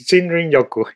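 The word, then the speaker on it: Shinrin-yoku: the Rejuvenating Practice of Shinrin Yoku. And you may want to repeat that for me in case, yet again, I haven't pronounced that correctly. – That's perfect. It's Shinrin Yoku.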